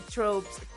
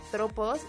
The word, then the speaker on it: tropos: Nos equivocamos todo el tiempo y decimos tropes porque somos unas pochas. Porque en inglés se dice tropes con. O sea, se escribe con E. Pero la versión correcta es tropos y en inglés tropes.